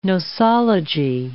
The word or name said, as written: nosology